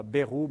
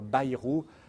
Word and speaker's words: Bayrou: On ne dit pas Bayrou, mais les Béarnais nous disent bien qu'il faut dire Bayrou.